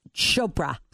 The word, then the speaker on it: Chopra: And does she say Chopra or Chopra? – She said Chopra.